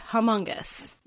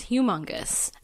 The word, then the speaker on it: Humongous: Okay, first things first, the pronunciation of Humongous. – Yeah, yep. – I have always said Humongous, but he clearly says Humongous, and yep.